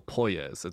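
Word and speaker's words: Poirier: I have learned the correct pronunciation of his name, Claudio Poyez. It's not Poyez, so correction there.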